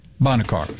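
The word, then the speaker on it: Bonnet Carré: And so they open up is it the Boncary something? Is that the right pronunciation? – Bonnet Car.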